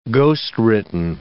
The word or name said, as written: ghostwritten